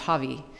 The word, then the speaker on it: Hawi: it's spelled H A W I, but it's pronounced Havi.